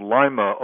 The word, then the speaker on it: Lima: I was raised in a town in the northwest called Lima, Ohio, spelled like the Lima know bean.